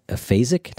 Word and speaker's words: aphasic: And you tell a story about a man who, he's an aphasic.